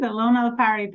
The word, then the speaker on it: Elona Lopari: Elona Lopari.